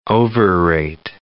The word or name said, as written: overrate